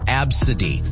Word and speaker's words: ABCDE: Like somebody's name was ABCDE, pronounced Absidy.